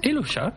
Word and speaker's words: Elosha: Et euh, Elosha, c'est comment, on le prononce exactement Elocha, comme, comme le écrit.